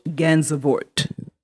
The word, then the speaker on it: Gansevoort: It's Fort Gansevoort.